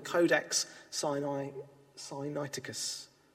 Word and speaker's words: Codex Sinaiticus: A pane of glass separates you from what's called the Codex Sinaiticus. Yes, that's how you say it.